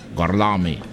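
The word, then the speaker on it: Gorlami: Lo pronuncio correttamente? – Gorlomi. – Sì. – Corretto.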